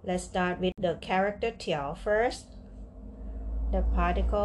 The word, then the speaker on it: 调: tiao, tiao, tiao.